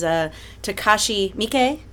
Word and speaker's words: Takashi Miike: And this is Takashi Miike.